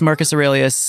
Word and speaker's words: Marcus Aurelius: Epictetus, Marcus Aurelius.